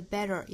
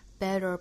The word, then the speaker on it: better: 位置，那在这个位置摆住了再去发后面的 the world world heal the world，后面 make it a better place，这句话里头我们看有很多 flap t 的处理哈， make it 有一个连读 it 这是一个 flap t， make it a 后面的 better 也是 flap t， better place。我们在周一早读里面其实讲到过这个 better 的发音了，练习的时候呢可以把前面的元音拖长然后我们去找这个弹的感觉 better 只有舌尖的位置在硬腭轻轻的一弹就卷进去了 better make it a better place， make it a better place， make it a better place。